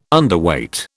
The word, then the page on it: Underweight: British English